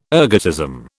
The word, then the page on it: ergotism: British English